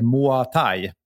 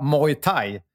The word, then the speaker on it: Muay Thai: Min fråga, när ska, när ska lära sig uttala, och då vet jag vad han syftar på, att jag säger Moa Thai. Eh, jag har hört många olika uttal. Inget kan kallas korrekt. Tänk bara, tänk bara Muay Thai, så är man ganska rätt ute.